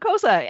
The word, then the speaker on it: Xhosa: how's the Xhosa? And that's how you say it if you can't make the clicky sound.